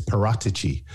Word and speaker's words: Paratici: First of all, your pronunciation was correct. I was speaking to someone at Spurs last weekend who was saying to me, it's Paratici rather than Paratici, which is what I thought it was.